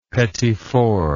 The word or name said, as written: petit four